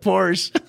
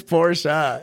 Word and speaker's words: Porsche: So I call it Porsche. But it's Porsche, and I get reminded of that on a constant basis.